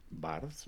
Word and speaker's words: Barthes: ¿puedes pronunciar esto bien en francés? – Barthes, ¿no? Como el señor Barnes